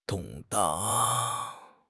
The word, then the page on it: 动荡: HSK6